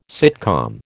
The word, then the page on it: sitcom: American English